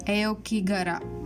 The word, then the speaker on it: Aokigahara: So Aokigahara, I think I'm pronouncing right now.